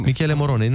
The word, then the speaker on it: Michele Morone: Exact cum se citește. Michele Moro... Morone. Michele Morone, nu e nimic complicat.